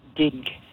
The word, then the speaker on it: digue: Digue. D-I-G-U-E.